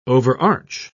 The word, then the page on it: overarch: British English